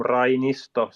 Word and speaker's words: Rainisto: So that's then Rainisto.